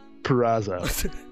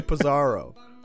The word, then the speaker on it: Purrazzo: Don't sleep on Deanna Purrazzo. – Can't even say the name properly. He wanted to say Pizarro.